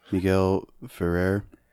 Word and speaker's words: Miguel Ferrer: Miguel Ferrer.